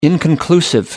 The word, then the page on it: inconclusive: American English